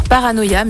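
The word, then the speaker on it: Para:noir: On passe maintenant à la présentation du groupe Paranoir, ou qui se prononce d'ailleurs Paranoia, mais du coup ça s'écrit Para, deux petits points noirs, donc Paranoia.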